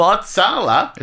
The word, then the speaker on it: Uppsala: God, we wish we had Tame Impala for Uppsala.